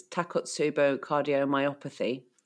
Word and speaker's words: Takotsubo cardiomyopathy: Actually, we've got our producers come up with the Amber, our producer, has just in the chat just said it's Takotsubo cardiomyopathy. Wow. Did I say that right as well? – You nailed the pronunciation, and Amber is absolutely that is amazing.